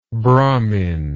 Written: brahminical

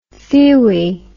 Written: thewy

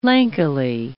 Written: lankily

